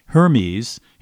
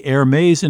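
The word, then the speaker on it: Hermès: So if you've been pronouncing that fancy French fashion company as Hermes, he'll teach you it's really Hermes in French, or in English, Hermes.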